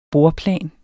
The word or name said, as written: bordplan